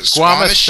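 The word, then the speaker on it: squamous: Is called, I'm probably going to mispronounce this, it sounds like Squamoosh. It's Squamish cell cancer, yeah.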